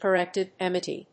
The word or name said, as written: core vitrectomy